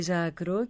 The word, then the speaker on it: Ζάκρο: Λοιπόν, ας μείνουμε όμως στη Ζάκρο και να μιλήσουμε μάλλον για την παρουσίαση της Ζάκρου. Καλά την προφέρω, η Ζάκρο στη Ζάκρου. – Ναι.